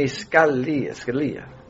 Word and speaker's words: Scalia: Supreme Court Justice, I'm told his name is pronounced Anthony Scalia, learned justice on the U.S.